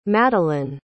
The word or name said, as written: Madelyn